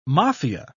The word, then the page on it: maffia: British English